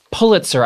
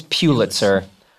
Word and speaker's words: Pulitzer: And I also didn't know that it was Pulitzer. I thought it was Pulitzer. He won a Pulitzer.